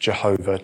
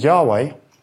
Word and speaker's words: Yahweh: So there was long debate about how this is pronounced. Is it Yahweh? Is it Jehovah? Depending on which version of how you pronounce the letters. But Yahweh, which we shall take, that is the proper name of God.